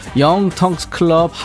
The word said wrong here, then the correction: 영턱스 클럽: 영텅스 클럽 왜 이렇게 발음이 안 되냐? 영턱스 클럽 하얀 전쟁 들어.